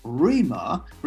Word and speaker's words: rhema: But the other word is, I think it's pronounced rhema, and it's R-H-E-M-A. Yep. Is it rhema? – That's right.